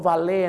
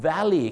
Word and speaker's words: valet: ที่จริงแล้วคำนี้ไม่ได้ออกเสียงว่า Valet นะครับคำนี้ก็ออกเสียงว่า Valley ครับผมถ้